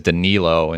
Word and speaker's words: Danilo: Ken tentatively put Danilo Gallinari's name in there, but I did a quick check on Basketball Reference, and it is Danilo instead of Danilo.